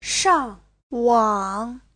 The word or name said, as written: shang4wang3